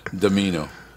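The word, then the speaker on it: Dimino: I'm going to start calling him Chris Domino, but he doesn't spell it that way. It's D-I-M-I-N-O, Domino.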